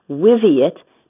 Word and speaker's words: Wiviot: And it's very easy to remember how to pronounce it, Wiviot, because it rhymes with idiot.